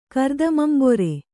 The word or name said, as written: ಕರ್ದಮಂಬೊರೆ